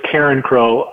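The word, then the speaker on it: Carencro: I want to talk a little bit about the culture shock of all of a sudden going to Karen, I'm not going to say it right, Carenco, Louisiana at Old Evangeline Downs. What was the culture shock going from Cincinnati down there? – It was a completely different world. It's Karen Crow.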